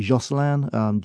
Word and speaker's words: Josselin: There's one called, we pronounce it Josselin, J-O-S-S-E-L-I-N.